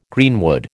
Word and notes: greenwood: American English

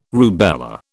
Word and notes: rubella: British English

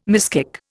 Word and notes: miskick: Indian

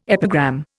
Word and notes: epigram: Indian

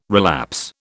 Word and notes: relapse: British English

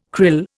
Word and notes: krill: Indian

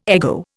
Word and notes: ego: Indian

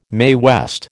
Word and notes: Mae West: American English

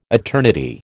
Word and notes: eternity: American English

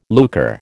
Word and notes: lucre: American English

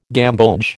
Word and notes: gamboge: American English

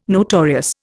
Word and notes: notorious: Indian

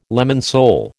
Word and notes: lemon sole: American English

